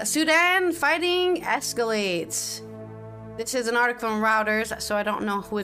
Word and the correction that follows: Reuters: Reuters is pronounced. Reuters. – Reuters, sorry. Sorry, Reuters, I should have known that because I'm Dutch and it uses the same phonetics.